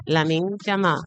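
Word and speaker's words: Lamine Yamal: Lamin Yamal.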